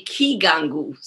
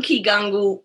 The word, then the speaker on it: Kigangu: And in the singular, a Kigangu. So one Kigangu, many Vigangu.